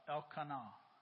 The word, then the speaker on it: Elkanah: It's Elkanah, the man of this house.